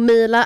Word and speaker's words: Meela: Och Mila är hur man uttalar det, men det stavas med M-E-E-L-A.